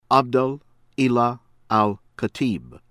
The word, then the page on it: Abdel Ilah Al Khatib: American English